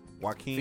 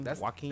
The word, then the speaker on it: Joaquin: Joaquin? – Phoenix? – Joaquin? Joaquin? – That's how you say it? – I think it's Joaquin.